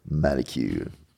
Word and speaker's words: Molekule: Like Mocool, but it's Malecule. Because it's Malecule.